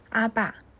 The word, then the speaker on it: 阿爸: 阿爸，阿爸，是效法亚兰文读音的希腊字，是犹太人称呼父亲的一个习惯。